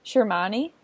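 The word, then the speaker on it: Sermanni: They named the coach for the USA national team, and it is Tom, how do you say his name? Shirmani? – That sounds correct.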